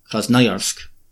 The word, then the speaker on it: Krasnoyarsk: In the Moscow accent, it's Krasnoyarsk. That middle syllable is the O is often pronounced as an A.